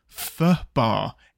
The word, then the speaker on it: FUBAR: It should be pronounced fur bar. FUHBAR. So FUBAR.